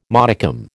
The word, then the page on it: modicum: American English